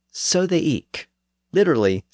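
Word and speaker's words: ik: Well, in parts of eastern England, especially around Norfolk, people pronounced that word with a hard K sound at the end. So they said eek, usually spelled I-K.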